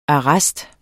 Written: arrest

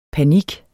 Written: panik

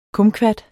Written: kumquat

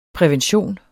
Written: prævention

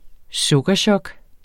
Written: sukkerchok

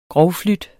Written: grovflute

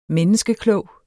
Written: menneskeklog

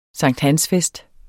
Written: sankthansfest

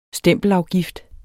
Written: STEMPELAFGIFTEN

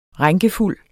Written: rænkefuld

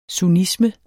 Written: SUNNISME

